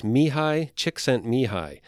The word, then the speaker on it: Csikszentmihalyi: And the author's name is Mihai Csikszentmihalyi, which it does not look like that at all. If you Google this book, you'll see what I'm talking about. But that is actually how you pronounce it.